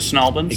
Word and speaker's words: St. Albans: Do you pronounce it Snalbans? – Exactly the same, because there are a lot of things we don't pronounce the same. And that is one that we do.